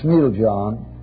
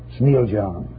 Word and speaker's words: Smiljan: In the year 1856, in the small Yugoslavian town of Smiljan, or Smiljan, pronounce in Yugoslav.